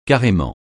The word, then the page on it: carrément: francais